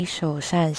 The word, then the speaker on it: Shou Shan: My parents named me Shou Shan Xie, S-H-O-U-S-H-A-N, which means kind and benevolent in Mandarin.